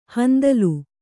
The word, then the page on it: ಹಿಂದೋಲ: hindōla